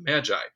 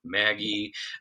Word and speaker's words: Magi: I think most church people will probably be familiar with Magi, but as you say, it's pronounced it's pronounced in different ways. Uh, magi, Maggie.